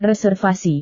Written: reservasi

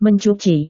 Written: mencuci

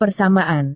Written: persamaan

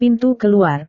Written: pintu keluar

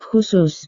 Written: khusus

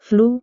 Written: flu

